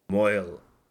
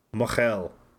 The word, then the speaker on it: mohel: M-O-H-E-L. Moyle. Mochel. But we pronounce it Moyle because it's funnier. Because it sounds like a guy from Brooklyn saying Merle.